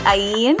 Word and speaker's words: Ayn: Is that how you say your name, by the way? – Yeah, it's a complicated name, isn't it? It's Ayn. – Uh-huh. – Yes, some people have said Anne, some people Eileen, Irene.